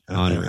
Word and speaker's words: Henry: Henry. – I don't know. – Thierry Henry.